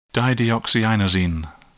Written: dideoxyinosine

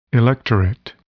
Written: electorate

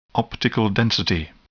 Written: optical density

